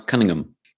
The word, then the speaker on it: Cunningham: I like the way you pronounce Cunningham. Cunningham. Well, Ron Howard would say, I'm Richie Cunningham. – There's no H in Cunningham. Come on. Not here. It's like, you know, it's like Birmingham, you know.